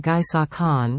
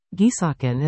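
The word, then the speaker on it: Guisachan: Gui is pronounced gu. The s is pronounced shish. And the ch is pronounced like g. But g like gap, not g like giraffe. Gisakin is the Gaelic word for pine forest.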